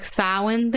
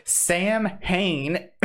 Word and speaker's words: Samhain: He, he said his ass said Sam Hain, and I said oh, it's Samhain. And then we talked about okay,